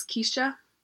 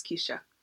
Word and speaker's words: Keisha: My name is Keisha. It's spelled with one E. I always get called Kesha, but I'm used to correcting people. My name is Keisha.